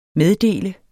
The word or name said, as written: meddele